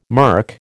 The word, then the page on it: murk: American English